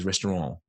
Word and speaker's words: restaurant: He pronounces it with a silent T, which I assume is how it's meant to be pronounced, it being, I assume, a French word. So he says restaurant.